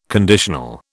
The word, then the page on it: conditional: British English